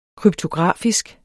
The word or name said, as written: kryptografisk